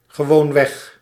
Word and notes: utterly: [ˈʌ.tə.li]; /ˈʌt.ə(ɹ).li/

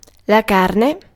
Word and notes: meat: /miːt/

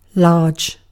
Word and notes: large: /lɑːdʒ/

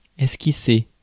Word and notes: outline: /ˈaʊt.laɪn/

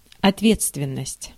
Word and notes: ответственность: /ɐtˈvʲet͡stvʲɪnːəsʲtʲ/; otvetstvennost

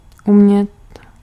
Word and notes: pouvoir: [pu.vwaʁ]; France (Paris)

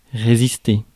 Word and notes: résister: France; [ʁe.zis.te]

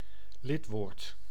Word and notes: lidwoord: /ˈlɪt.woːrt/

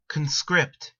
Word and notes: conscript: American English

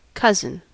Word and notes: serkku: /ˈser.kːu/

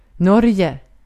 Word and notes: Norge: /ˈnɔrjɛ/

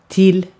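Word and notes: till: /tɪl/; Swedish

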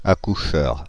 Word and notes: accoucheur: France (Île-de-France); [a.ku.ʃœʁ]

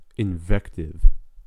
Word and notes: invective: American English; /ɪnˈvɛktɪv/

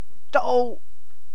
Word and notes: d'oh: /doʊ/; /doʊʔ/; /dəʊ/; /dəʊʔ/

